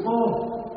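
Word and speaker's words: vô (無): Cái chữ mô á, chữ mô nó nguyên chữ Hán nó đọc là chữ vô. Chữ Hán đọc là chữ vô chứ không phải là chữ mô, mà là chữ vô.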